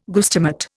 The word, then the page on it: guestimate: Indian